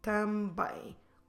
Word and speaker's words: também: Também. Como se escreve também? T-A-M-B-E com acento M.